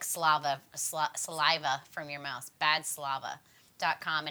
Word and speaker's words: Badslava: It's not like saliva. – Oh, I thought it was... Well, I don't understand the word. I understood what you said. – But I spelled it correctly. – You spelled it correctly. – I said it wrong. – No. Slava is S-L-A-V-A.